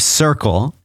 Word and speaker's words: Cirkul: It's the name of the sponsor is Circle, and it's spelled C-I-R-K-U-L.